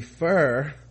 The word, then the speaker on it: pho: pho is pronounced pho or even more accurately fur f-u-h-r damn that's like the sixth different anyway